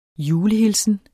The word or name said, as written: julehilsen